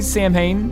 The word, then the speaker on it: Samhain: He said, "What direction did you think Sam Hain or Saw Wynn?" How do you say? Do you say Sam Hain? – Well, I mean, I guess it's strictly speaking, it's Samhain. Samhain.